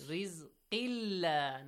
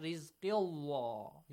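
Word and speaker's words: رزق الله: We said if the word Allah is preceded by a kasra, the letter lam of the word Allah is pronounced lightly. – Yes. – Rizqilla, not rizqillah.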